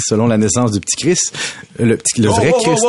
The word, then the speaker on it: Christ: Le petit Christ, hein, c'est bien important de prononcer le T, toi qui es bien connu dans ton catéchisme.